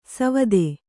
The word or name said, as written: ಸವಿಧೆ